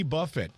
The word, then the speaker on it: Buffett: Jimmy Buffett, B-U-F-F-E-T-T, which is spelled the same as buffet, but pronounced differently.